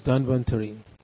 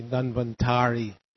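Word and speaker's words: Dhanvantari: Notice there's no long A. Quite often devotees say Danvantari. There is no such thing here. No long syllable anywhere. It's Danvantari. In Sanskrit, when there's no diacritic, everything is given the same stress. Danvantari, not Danvantari.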